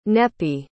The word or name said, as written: neppie